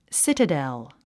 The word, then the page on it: citadel: sítədl; スィテダァォ